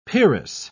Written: peeress

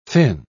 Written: fin

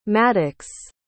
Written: Maddux

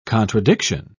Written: contradiction

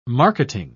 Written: marketing

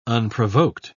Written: unprovoked

